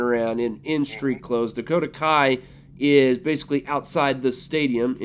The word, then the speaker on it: Kai: Is that how you pronounce the last name, Kai? – Yes.